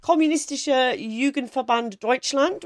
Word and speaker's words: Kommunistische Jugendverband Deutschland: She joins the Kommunistische Jugendverband Deutschland, which is very good pronunciation.